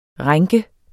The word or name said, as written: rænke